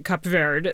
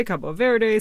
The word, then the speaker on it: Cabo Verde: just heard the man himself say Cabo Verde, the right. English pronunciation. Yes. You and I are going with Portuguese, which is what people there would say, Cabo Verde, same country.